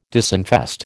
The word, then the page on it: disinfest: American English